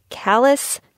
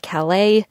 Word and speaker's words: Calais: A quick recap, if you only take a few names from this episode, it is Calais, not Calais, Charlotte, not Charlotte, and Wolcott, not Walcott.